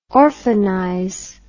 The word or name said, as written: orphanize